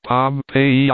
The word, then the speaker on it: Pompeii: The buried city of Pompeii, they pronounce it Pompeii.